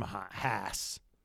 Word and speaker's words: Haas: Nikita Mazepin has been dropped from Haas. – Yes. – Which rhymes with ass.